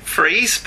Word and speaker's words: Fries: It's like Freeze, but it's spelt Fries.